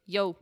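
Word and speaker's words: io: You know what they said? – Yo. – They said yo. That's right. Spelled I-O, but pronounced yo.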